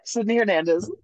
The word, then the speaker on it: Sydney Hernandez: Yeah, Sydney Hernandez.